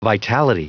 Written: vitality

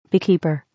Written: beekeeper

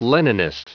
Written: leninist

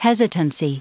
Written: hesitancy

